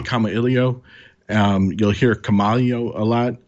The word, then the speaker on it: Kamailio: And so, you know, I'll say Kamailio, and that's the way I'll choose to to refer to it.